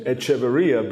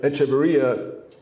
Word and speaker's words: Echeverria: So, you know, people have trouble pronouncing my last name, Echeverria, but Echeverria sounds like Echeverria.